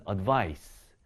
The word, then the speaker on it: advice: 지금 여기 앞에 있는 se로 끝나는 것은요. 발음이 advise가 되고요. 이 발음으로 끝납니다. advise. 뒤에 있는 c로 끝나는 것은 advice. 이 발음으로 끝나게 되겠죠.